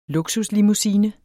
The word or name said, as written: luksuslimousine